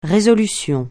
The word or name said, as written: résolution